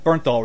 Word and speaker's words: Bernthal: John Bernthal? – Bernthal return.